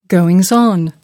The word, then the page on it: goings on: American English